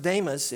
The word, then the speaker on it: Demas: He said, "For Demas," it's actually pronounced Damas in Greek, but "for Demas, in love with his present world, has deserted me and gone to Thessalonica.